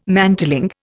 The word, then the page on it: mantling: Indian